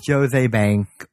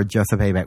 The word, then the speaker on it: Joseph A. Bank: It's Jose Bank or Joseph A. Bank, whatever you want to call it.